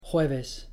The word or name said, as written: jueves